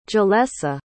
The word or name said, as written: jillesa